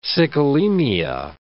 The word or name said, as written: sicklemia